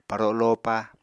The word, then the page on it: paro lopa: Apatani